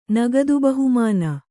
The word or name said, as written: ನಗದುಬಹುಮಾನ